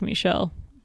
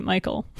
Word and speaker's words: Michel: Oh, sure, when you say it like Michel. But if you say it Michael. – Yes, if you say the name wrong, it doesn't sound French.